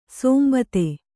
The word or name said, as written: ಸಂಬಾಧ